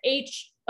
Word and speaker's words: Housel: I think it's pronounced Hauschel. Morgan, he used to write for The Motley Fool. Okay. I think it's Morgan Housel is how you pronounce it. Housel.